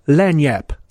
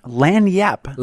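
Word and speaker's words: lagniappe: So this is like an accoutrement, or as they say in New Orleans, lanyap, little something extra. – Lanyap. – Lanyap. It's spelled crazy. L-A-G. I, and there's a couple P's in there, an E thrown in, but lanyap is how it's pronounced, and it means a little something extra.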